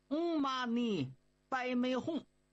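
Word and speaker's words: 唵嘛呢叭咪吽: 嗨，发音呢还真就是挺难的，它念作嗯嘛呢白梅哄。咱没说嘛，这是梵文呢，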